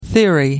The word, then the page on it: theory: American English